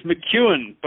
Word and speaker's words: McKeown: It's McKeown.